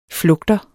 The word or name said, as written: flugter